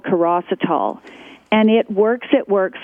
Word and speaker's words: chirositol: It's spelled C-H-I-R-O-S-I-T-O-L, Kerecitol.